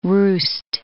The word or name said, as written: roost